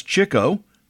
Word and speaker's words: Chico: Leonard was known as Chico. The second C goes with the first syllable.